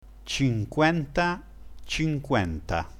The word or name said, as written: cinquenta